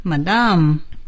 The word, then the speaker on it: Madame: Yes, I hope you know this person, Madame Curie. OK, 我们说今天的课文很好，但是我们在学课文之前先看一下单词。 – New words, new page. – 我们从夫人这个词开始。 – Madam. – Madam. It sounds French, right? – Yes. – Madam, 这是英语。法国人怎么读呢？ Madame. Madame, that's French.